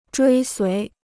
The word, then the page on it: 追随: zhuī suí